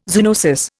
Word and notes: zoonosis: Indian

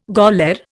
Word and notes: gaoler: Indian

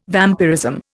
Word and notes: vampirism: Indian

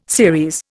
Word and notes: series: Indian

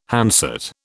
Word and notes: handset: British English

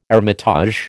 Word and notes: hermitage: American English

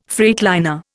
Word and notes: freightliner: Indian

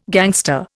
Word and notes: gangster: Indian

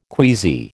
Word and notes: queasy: American English

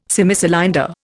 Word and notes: Semicylinder: Indian

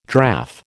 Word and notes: draff: American English

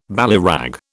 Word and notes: ballyrag: British English

